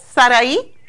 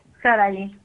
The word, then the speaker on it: Sarai: Así es, Sarai. Sarai. – Ok, ¿y cómo se escribe? – S-A-R-A-I.